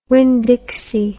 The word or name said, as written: winn dixie